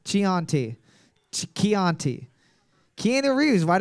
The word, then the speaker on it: Chianti: It's pronounced Chianti. – I'm tired of the fucking English language. Why they put an H in there? If you don't fucking announce, pronounce the H.